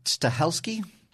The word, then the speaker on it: Stahelski: And this Chad Stahelski – I think I'm saying his name wrong. – I think it's Stahelski.